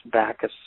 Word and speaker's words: Bakhus: Message me on Facebook, and it's Bacchus Saba. – And you want to spell that? – U-S. – Mm-hmm. – Yeah, B-A-K-H-U-S.